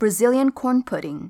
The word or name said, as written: brazilian corn